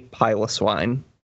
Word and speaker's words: Piloswine: It's not pronounced Piloswine. If you say Piloswine, because Pylos, the prefix Pylos indicates hairy. So if any of you out there have ever said Piloswine because it's like a pillow because it's soft, you can just...